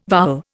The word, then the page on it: vowel: Indian